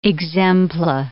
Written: exempla

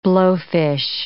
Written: blowfish

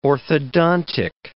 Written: orthodontic

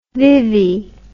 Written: vivi

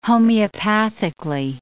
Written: homeopathically

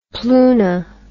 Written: pluna

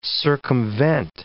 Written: circumvent

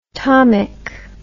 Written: tomic

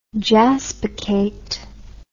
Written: jaspachate

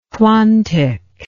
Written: quantic

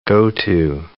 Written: go-to

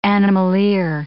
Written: animalier

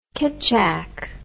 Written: ketchak

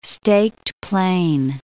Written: Staked Plain

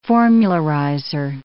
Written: formularizer

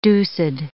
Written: deuced

